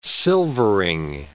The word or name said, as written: silvering